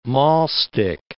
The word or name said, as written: maulstick